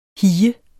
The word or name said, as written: hige